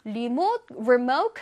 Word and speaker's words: remote: 자, 이거는 MOTE, remote에서 MOTE 부분에 강세가 들어가요.